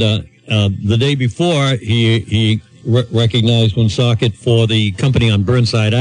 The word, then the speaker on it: Woonsocket: Nationally, the president even pronounced Woonsocket, Rhode Island properly.